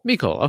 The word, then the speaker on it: Mecole: Mecole? How do you pronounce that? – Mecole, okay.